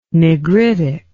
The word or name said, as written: negritic